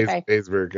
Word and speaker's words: Haysbert: Also with Dennis Haysbert. I think that's how you pronounce his last name. Sorry, Haysbert.